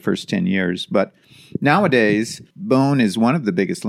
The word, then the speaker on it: Boon: It's spelled B-O-O-N, which sounds like Boone, but I think the pronunciation is more like bone.